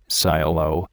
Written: silo

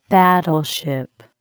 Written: battleship

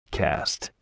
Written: cast

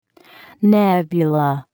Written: nebula

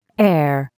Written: heir

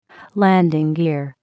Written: landing gear

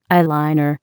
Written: eyeliner

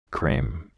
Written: cream

